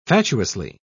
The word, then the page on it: fatuously: British English